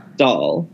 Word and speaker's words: Dahl: First of all, I did not realize all of the controversy around the book and how do you pronounce his last name? – Dahl.